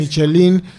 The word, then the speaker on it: Michelin: En español nosotros estamos acostumbrados a castellanizarlo así como es. Michelin.